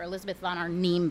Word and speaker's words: Elizabeth von Arnim: That Jane Austen should be, for Catherine Mansfield, for Elizabeth von Arnim, by the way, which is how you pronounce her name, and for somebody like Vanessa Bell, that they would live very experimentally and yet still maintain a passion for a fundamentally...